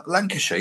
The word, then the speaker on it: Lancashire: Is that the right way to say it? Lancashire. – Yeah, Lancashire, yes. Yeah. That's right. Yeah, that's the way.